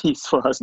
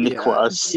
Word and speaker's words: Niçoise: It's, it's a salad Niçoise, not a not a Nicoise.